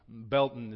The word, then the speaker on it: Baileyton: If you're new, and maybe you're not from Greene County, Baalton is that way. Yes, that way, right? Yes. And it's spelled B-A-I-L-E-Y-T-O-N. When I first heard locals talking about Baalton out there, I thought, what does a belt have to do with an area in Greene County? B-E-L-T-O-N is how I heard it.